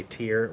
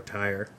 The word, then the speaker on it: Tyre: So Jesus is moving on from that conversation and out of Galilee to a place spelled T-Y-R-E, which you can pronounce any number of different ways. You could say tear or tire, either one, which is a region just next to, just like neighboring Galilee.